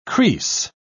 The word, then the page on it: creese: British English